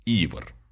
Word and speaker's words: Ивар: Ударение на «и», кстати. Ивар. А то мало ли, как его прочтут. Может, как командир Дирк.